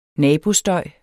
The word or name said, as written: nabostøj